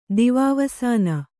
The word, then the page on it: ದಿವಾವಸಾನ: kannada